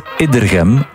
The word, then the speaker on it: Iddergem: Mag ik even zeggen dat Iddergem met een korte E wordt uitgesproken, gevolgd door dubbele medeklinkers.